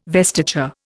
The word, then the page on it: vestiture: Indian